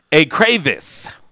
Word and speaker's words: écrevisse: It comes from the French word pronounced écrevisse. Okay.